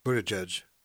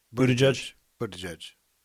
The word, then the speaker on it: Buttigieg: Buttigieg. – That will be in Charlotte, August 24th through the 27th, a month and a half after. – Buttigieg.